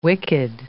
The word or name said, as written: wicked